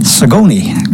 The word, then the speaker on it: Sagona: Now, the song we're going to hear Joe Drake sing is one that he wrote, and it's called the Sagona Song, S-A-G-O-N-A. However, apparently that is pronounced Sagoni, because that's the way Joe Drake pronounces it in the song.